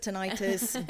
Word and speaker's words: tinnitus: Tinnitus, but it doesn't matter. But Americans pronounce it tinnitus, Brits pronounce it tinnitus.